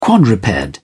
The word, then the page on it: quadruped: British English